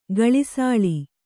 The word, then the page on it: ಗಳಿಸಾಳಿ: gaḷisāḷi